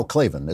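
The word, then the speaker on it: Klavan: Over at the American Mind, Spencer, I think it's pronounced Clavin. No, Clavin. It's my son, Spencer Clavin. – There are no meetings. – That's right. And the A's are long.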